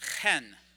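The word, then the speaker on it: chen (חן): In the Hebrew, it's pronounced chen. Let's all say it together. Chen. You have to get that in there.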